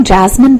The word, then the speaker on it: Jazmin: Uh, just my name, Jazmin Beltran.